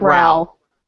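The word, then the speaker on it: Rowell: Rainbow Roll, is that how you pronounce her name, or is it Rowl? It sounds rowling, not like bowling. – Mm-hmm. Exactly. Like rowling, not rolling.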